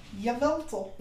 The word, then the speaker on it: Yveltal: One's a Kyogre, and one's a Veltal. Yveltal. I don't know how to pronounce it. I think it's Veltal, though. – Yveltal.